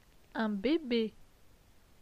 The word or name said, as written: Un bebe